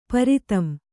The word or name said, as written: ಪಿರಿದುಂ